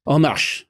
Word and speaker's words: En marche: En marche. En marche, heel goed.